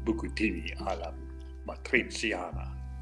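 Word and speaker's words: bucatini all'amatriciana: Well, please tell me more about bucatini alla matriciana, and I am probably not pronouncing it nearly as correctly as you can. – You are doing an excellent job of pronouncing bucatini alla matriciana.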